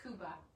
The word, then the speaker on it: Khuba: The first picture I'm going to talk about is the Aramaic word, and it's pronounced Kuba, or Kuba, K-H-U-B-A. Kuba, that's the noun, and the verb is Kav, K-H-A-V, like V as in Victor.